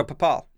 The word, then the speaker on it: Bhopal: So, Bhopal.